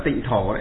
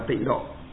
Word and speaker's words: tịnh độ: Trong chữ Hán nó là tịnh thổ đấy. Nhưng mà nhà Phật chúng ta đọc là tịnh độ.